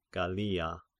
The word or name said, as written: Gallia